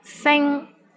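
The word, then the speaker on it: Sing (星): Sing, which means star, S-I-N-G-1. Here, the initial sound is S, and final sound is ing, ing, and the syllable is in the first tone.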